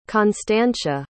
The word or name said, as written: constantia